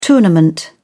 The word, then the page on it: tournament: British English